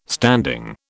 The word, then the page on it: standing: British English